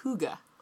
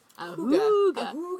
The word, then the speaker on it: hygge: And it's pronounced hygge. – Oh, nice. Hygge.